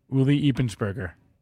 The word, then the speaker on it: Ulrich Eppensperger: Can you correct me? – Ulrich Eppensperger. Yeah.